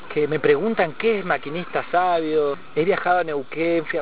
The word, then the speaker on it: Maquinista Savio: Hasta decían Maquinita, lo pronunciaba mal.